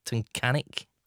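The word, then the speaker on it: Tunkhannock: It says it rhymes with mechanic, and then it looks like Tunkanuk, so it must be Tunkanic.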